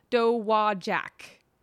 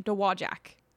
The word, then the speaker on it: Dowagiac: Pronounced Dowagiac, Michigan. Dowagiac, Michigan. Yay.